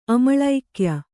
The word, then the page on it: ಅಮಳೋಕ್ಯ: amaḷōkya